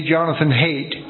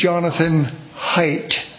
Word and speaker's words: Jonathan Haidt: The guy pronounces his name wrong. I, too, say Jonathan Haidt, but no, he will firmly correct you. It's Jonathan Haidt. It's like H-I-T-E. That's how he says it.